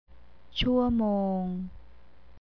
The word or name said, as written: shuamong